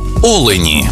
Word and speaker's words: олені: в однині і в множині наголос падає на перший склад: олень та олені.